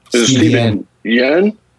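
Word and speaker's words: Steven Yeun: That's why, our cowboy guy, Stephen Yen. Is that the right pronunciation? Yes.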